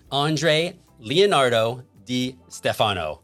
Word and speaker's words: Andre Leonardo Di Stefano: I'm here with my friend from New Jersey, Andre Leonardo Di Stefano.